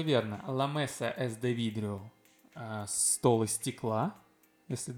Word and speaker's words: vidrio: La mesa es de vidrio. – De vidrio. В не читаем. У них В произносится как бы. La mesa es de vidrio.